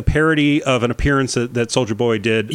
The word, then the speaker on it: Soulja Boy: Yes, Soulja Boy.